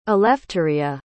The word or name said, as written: elefteria